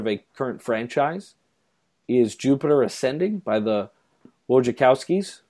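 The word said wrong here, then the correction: Wachowski: Wachowskis, isn't that how you say it? Wachowskis, there you go.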